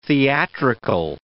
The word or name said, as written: theatrical